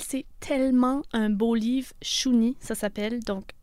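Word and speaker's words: Shuni: Et Julie, c'est un prénom qui serait prononcé Chuni euh, par ses ancêtres autochtones qui n'auraient pas pu prononcer le J et le L. Alors Chuni, c'est Julie en Innu.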